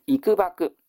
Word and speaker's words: 幾何: A の 13 番、幾縛、この幾何という漢字を幾縛と読みます。